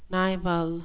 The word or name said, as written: nival